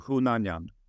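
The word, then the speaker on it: Hunanyan: så sa de alltid Yunnan Jan. Så det var alltid folk som frågade om jag var från Indien, just för att de uttalade mitt namn på det sättet. Men jag valde att lägga till H och Y så att det uttalas som det ska. – Och hur uttalar man det då, för att vara exakt då? – På ett riktigt armeniskt sätt skulle man säga Hunanjan.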